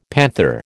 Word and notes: panther: American English

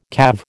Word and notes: calve: American English

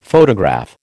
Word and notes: photograph: American English

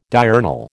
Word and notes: diurnal: American English

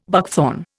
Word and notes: buckthorn: Indian

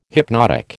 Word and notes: hypnotic: American English

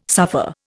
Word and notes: suffer: Indian

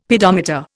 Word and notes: pedometer: Indian

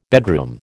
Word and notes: bedroom: American English

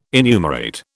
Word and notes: enumerate: British English

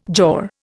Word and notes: jaw: Indian

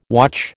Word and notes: watch: American English

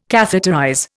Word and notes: catheterize: Indian

